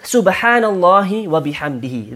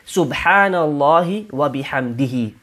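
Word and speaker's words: Subhanallah wa bihamdihi (سبحان الله وبحمده): So, Subhanallah wa bihamdihi. This is the way we pronounce this dhikr. Very easy, very simple, very short. Subhanallah wa bihamdihi.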